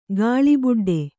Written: ಗಾಳಿಬುಡ್ಡೆ

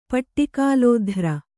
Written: ಪಟ್ಟಿಕಾಲೋಧ್ರ

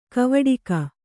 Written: ಕವಡಿಕ

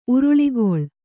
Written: ಉರುಳಿಗೊಳ್

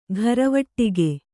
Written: ಘರವಟ್ಟಿಗೆ

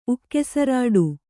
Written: ಉಕ್ಕೆಸರಾಡು

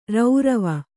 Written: ರೌರವ

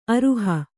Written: ಅರಿಹ

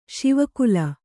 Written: ಶಿವಕುಲ

